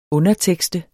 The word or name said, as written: undertekst